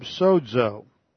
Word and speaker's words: sozo: The Greek word that is comparable to Yeshua is the verb sozo. S-O-Z-O, and those are long O's with that omega there.